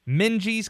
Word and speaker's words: Minges: Then they're visiting East Carolina University, my old stomping grounds, Minji's Coliseum, not to be confused with Mingies or Minges Coliseum. A lot of people mispronounce the name of that building, I've come to find out.